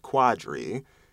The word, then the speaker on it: Qadree: My name is spelled Q-A-D-R-E-E, no U, but it is pronounced Quadri, which indicates that to a lot of people that there should be a U there.